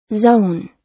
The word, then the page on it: zone: American English